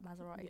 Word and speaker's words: Maserati: Maserati.